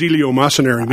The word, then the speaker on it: eleemosynary: What does eleemosynary mean? – I love that word. That's a great word, and you pronounced it perfectly, by the way.